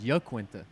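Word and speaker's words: Iaquinta: I heard that Al's real pronunciation is Yaquinta.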